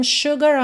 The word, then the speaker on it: sugar: É sugar, sempre atenção que não é sugar, né? Sugar. Nem o S tem som de S, nem o U tem som de U.